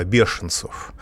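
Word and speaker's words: беженцев: Надо сказать, что еще до приглашения Меркель э, более чем миллиона э, бешенцев мы произносим это слово через «ж», а русские э, европейцы произносят это слово через «ш» от слова «бешенство», потому как эти люди себя ведут в Европе.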